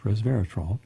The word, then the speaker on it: resveratrol: From University College London, comes a study published in Scientific Reports that resveratrol, res, R-E-S, vera, V-E-R-A, trol, T-R-O-L, three syllables, resveratrol,